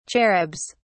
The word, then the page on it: cherubs: British English